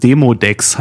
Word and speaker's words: Demodex: Ich habe es falsch ausgesprochen. Demodex heißt es.